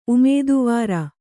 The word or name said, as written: ಉಮೇದವಾರಿ